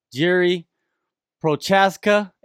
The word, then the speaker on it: Jiří Procházka: Join me right now on Kumite TV is Rising Light Heavyweight Champion, Jiří Procházka. Am I saying that right, Jiří? – Yeah, that's right.